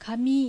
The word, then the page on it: Kami: Japanese